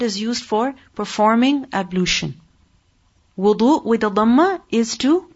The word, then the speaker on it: Wudu: So what's the correct pronunciation next time you want to go do ablution? What will you say? Wudu. If you say wadu, then that is incorrect pronunciation.